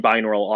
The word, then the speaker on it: binaural: binaural audio. – That's correct. Binaural. Also very well pronounced.